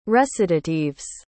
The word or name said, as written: recitatives